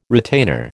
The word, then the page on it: retainer: American English